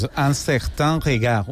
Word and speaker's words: Un Certain Regard: un certain regard, se diu el premi. Corregeix-me si m'equivoca, tu tens una molt bona pronunciació del francès. – Sí, és un certain regard, una cosa així.